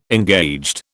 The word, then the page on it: engaged: British English